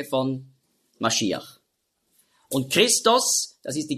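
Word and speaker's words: Mashiach (משיח): Und Messias, das ist einfach die Aussprache, die Griechen konnten nicht Mashiach sagen. Weil es gibt ja kein Sch-Laut im Griechischen. Darum sagen auch die modernen Griechen, die sagen ja nicht Fisch, sondern Fis. Also sie haben schon gelernt. Also darum wurde aus Masch, wurde Mess. Und dann Iach mit dem Het am Schluss. Mashiach hat man früher ausgesprochen. Mashiach. Da hatten sie auch Mühe.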